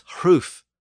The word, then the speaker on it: hruf: So a word like ring was hring in Old English, spelled H-R-I-N-G. And roof was hruf, H-R-U-F.